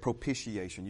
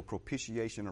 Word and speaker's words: propitiation: Some people, and both pronunciations are correct, propitiation. You can go propitiation or propitiation, and I'm saying both because hopefully that word's going to stick in your mind because it's a very important word.